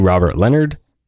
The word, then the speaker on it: Robert Leonard: My username is the Robert Leonard.